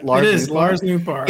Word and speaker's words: Nootbaar: Lars Nootbaar.